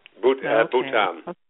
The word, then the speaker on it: Bhutan: And you were talking about your pictures, and you were in Bhutan, right? Is that how you pronounce it? – Yes. Bhutan.